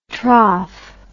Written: troph

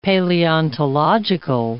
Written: paleontological

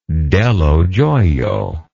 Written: Dello Joio

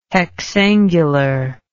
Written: hexangular

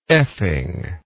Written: effing

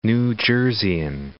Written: New Jerseyan

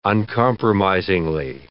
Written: uncompromisingly

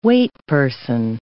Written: waitperson

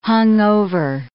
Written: hungover

